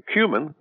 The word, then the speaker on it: cumin: Um, but you should know that um, if you look up in a dictionary, you'll find that the uh, that that is a, apparently a brand new pronunciation going around. – Uh, the traditional pronunciation is cumin. In fact, it sometimes has been spelled with two m's.